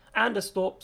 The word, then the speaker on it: Anderstorp: I think it's Anstrup, which is the racetrack again. I have no... Anderstorp, sorry, I knew my pronunciation was not going to be correct on that.